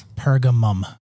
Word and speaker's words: Pergamum: Did I say that right? Pergamos? – Pergamum. – Oh, well, it doesn't say that here. This Pergamos. Pergamum? Mm-hmm. – Pergamum. – Or apparently Pergamos. – Yeah, there you go.